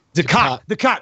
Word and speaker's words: Dukat: Dukat.